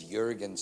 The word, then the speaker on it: Jurgen: And he walked away, and I just thought, he must have me muddled up, because my name is Jurgen, spelt with a J, looks like Jurgen.